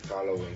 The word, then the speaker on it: Halloween: Feliz Howling. ¿Cómo se dice? Halloween. Feliz Halloween, grupo.